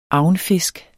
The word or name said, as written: agnfisk